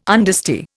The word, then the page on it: understeer: Indian